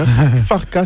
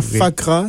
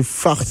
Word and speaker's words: Farka: Je prononce bien, Farka, c'est pas Fakra, c'est Farka.